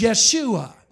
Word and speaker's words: Yeshua: It was Yeshua. That's how you would say his name in Hebrew.